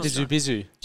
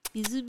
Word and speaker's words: Bisou: Went and met him and he works at Bisou, or how do you say that? – Oh. – Bisou. – Jeez. – Means kisses in French. – Bisou.